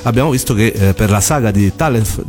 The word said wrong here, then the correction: Tales: di Tales of, Tales. – Tales of, ovviamente, io sbaglio ovviamente come sempre la pronuncia, e ehm, c'era addirittura una mostra lì a, um, a Tokyo.